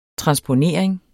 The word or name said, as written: transponering